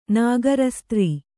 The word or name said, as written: ನಾಗರಸ್ತ್ರೀ